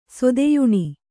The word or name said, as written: ಸೊದೆಯುಣಿ